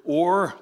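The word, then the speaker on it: or (אור): So the word light in the Old Testament is the Hebrew word or, transliterated O-W-R, pronounced like an oar for a boat or iron ore.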